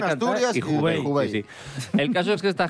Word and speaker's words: Hubei: El caso es que, por lo visto, en la región eh, China de mm, Hubei. No sé si se pronuncia así. – Sí, Hubei, sí.